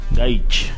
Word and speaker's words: Gaich: Gaich. Ah, así se pronuncia.